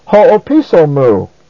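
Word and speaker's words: ho-o-piso mu (ὁ ὀπίσω μου): So literally, at the end of line 34, this phrase is pronounced ho-o-piso mu, as if it's all one word.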